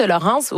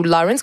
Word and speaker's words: Lawrence: Puis la voix de Laurence, ou Lawrence, comme je vous dis, ça s'écrit L-A-W-R-E-N-C-E, est vraiment belle et ça nous charme automatiquement.